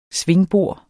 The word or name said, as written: svingbor